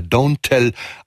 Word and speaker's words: DNTEL: Hier geht's etwas schneller weiter mit einem Titel aus dem neuen Album von Jimmy Tamborello, besser bekannt als DNTEL, und da wird er Don't Tell ausgesprochen, einstmals eine Hälfte dieser großartigen Elektroband The Postal Service mit dem Death Cab for Cutie Sänger Ben Gibbard an seiner Seite.